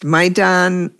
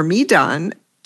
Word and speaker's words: Maidan: Maidan, pronounced Maidan, Maidan, or Midan, doesn't carry a passport, writes Rose Previtt.